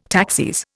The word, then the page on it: taxis: Indian